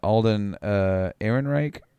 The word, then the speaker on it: Alden Ehrenreich: And then Alden Ehrenreich.